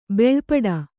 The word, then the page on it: ಭಾಳಪಟ್ಟ: bhāḷa paṭṭa